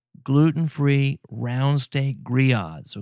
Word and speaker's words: grillades: It's got two L's in it, kind of like Spanish. You know, in Spanish, the two L's, the A-ye is going to be pronounced as a y. Well, that's how gree-yahds is pronounced. But it looks like grillades or something like that, but gree-yahds.